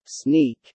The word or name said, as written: sneak